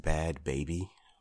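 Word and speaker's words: Bhad Bhabie: So I guess the name is pronounced Bad Baby.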